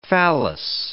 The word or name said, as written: phallus